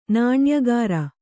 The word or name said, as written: ನಾಣ್ಯಗಾರ